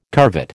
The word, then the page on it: curvet: American English